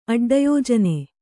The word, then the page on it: ಅಡ್ಡಯೋಜನೆ: Kannada